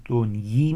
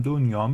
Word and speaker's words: دنیی: اینم من دنیی میخونم، آقای خرمشاهی نوشتن بایستی تلفظ های سر راست ملاک باشدین. چه حرفی آخه، بایستی ببینیم اون دوره چی میگفتن. اینجا هم در حافظ بارها در نسخه ها میبینیم هم دنیا میشه خون هم دنیی، ولی حافظ دنیی آورده، فتوی آورده، فتوی پیر مغان دارم و قولیست قدیم.